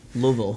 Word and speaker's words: Louisville: I brought you the Louisville Pale, uh, and it's spelled, I should say, L O O hyphen a hyphen V U H L. Yeah. So you, you kind of have this phonetic pronunciation of Louisville that I never got before I knew people from there.